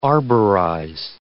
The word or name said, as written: arborize